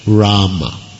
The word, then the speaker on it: Rama: Well, in the Sanskrit mantra, the correct pronunciation is Hare with the ha and Rama.